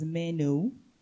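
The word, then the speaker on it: meno (μένω): But when we really look at it, the word abide, the actual word that was used in this passage is derived from the Greek word that is pronounced as meno.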